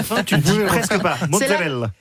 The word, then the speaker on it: mozzarella: Loana Belmondo, on parle de mozzarella aujourd'hui. Déjà, est-ce qu'on prononce bien ? C'est mozzarella ? – Mozzarella. – Mozzarella. – Vous le dites très très bien.